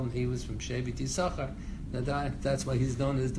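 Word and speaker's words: Yisoschar (יששכר): The reason why we pronounce it Bnei Yisoschar, because that's the way he pronounced it by Kriyas HaTorah.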